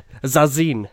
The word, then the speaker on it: Zazin: Har du sagt Sasslin hela den där tiret, eller? – Finns det... Vad fan heter hon då? – Zazin. – Zazin, okej. – Rätt uttal. – Zazin heter hon, har du ingenting mellan öronen?